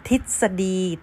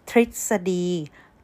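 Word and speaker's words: tisadi (ทฤษฎี): Some of people will say tisadi, tisadi, which is not completely wrong, but it's just not a correct way to say it. The correct way to say it, tisadi, tisadi, there will be a little R along the way.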